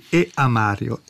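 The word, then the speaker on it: E. A. Mario: Ecco, non è che non voglio dire il nome, e si pronuncia proprio così, E a Mario.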